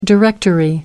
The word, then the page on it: directory: American English